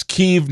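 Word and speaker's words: Kyiv: And then, of course, they were afraid using the word Kiev would be disrespectful because it's Kiev now, because that's how you pronounce it in Ukrainian. It's not Kiev. Kiev is the Russian version of it.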